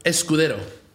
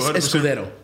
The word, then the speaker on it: Escudero: Did I say it? – Escudero. – Say it again. – Escudero or Escudero. – Escudero. – I always like to pronounce it in the Spanish pronunciation.